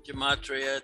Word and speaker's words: Gematria: He is a multidisciplinary researcher, including, what is that? Gematria? Did I say that right? – Gematria, it's numbers behind the words.